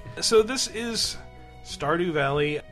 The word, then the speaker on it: Stardew: Uh, did I uh, say the name right? Stardew. – I said it right. You said Yeah. Sardew. – I did not say Sardew. – Yeah, It's did. Accent on the O.